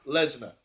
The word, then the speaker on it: Lesnar: Because I believe it's pronounced Brock Lesnar. Very good, very good.